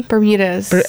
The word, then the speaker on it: Bermudas: Bermudas. – I will butcher that. – Like the Bermuda Triangle.